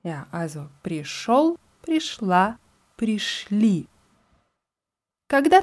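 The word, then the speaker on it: пришёл: Und das Wort пришел schreibt man mit jo obwohl es wie O ausgesprochen wird.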